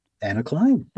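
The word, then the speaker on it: Anna Clyne: Is it Anna Klein? – Anna Klein. – Okay. And it's called Pivot. So tell us about yes. – Anna Klein and Pivot. – So Anna Klein is a UK-born composer who has been residing in the US for a number of years now.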